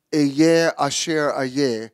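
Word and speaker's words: Eyeh Asher Eyeh (אֶהְיֶה אֲשֶׁר אֶהְיֶה): It says, "I am that I am," and there it is in the Hebrew, is pronounced "Eyeh Asher Eyeh," is the common English translation of the response that God used in the Hebrew Bible when Moses asked for his name.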